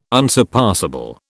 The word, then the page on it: unsurpassable: British English